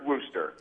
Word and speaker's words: Worcester: If you're not from Worcester, it's Worcester. – Yeah, it's Worcester because I was there and they said, it's not Worcester, it's Worcester.